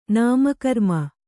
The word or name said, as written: ನಾಮಕರ್ಮ